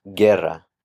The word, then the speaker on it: guerra: Notice that with the word guerra, if the G is followed by a UE, then the U will be silent.